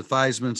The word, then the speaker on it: Theismann: I mean, you know, Joe Theismann put out a tweet saying he literally changed the spelling or the pronunciation of Joe Theismann to Theismann, so it would rhyme with Heisman.